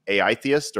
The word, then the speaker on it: AItheist: Do you say it as the AI-theist or the atheist? How would you pronounce the title of your book? – Yeah, I just say AI theist.